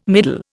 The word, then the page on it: middle: Indian